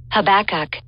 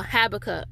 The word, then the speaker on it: Habakkuk: So I want you to go with me to Habakkuk, and you can actually say this in two different ways. I'm going to share the pronunciation of this specific prophet. – Habakkuk. – All right, so those are the two different ways that you can say Habakkuk.